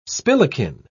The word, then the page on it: spillikin: British English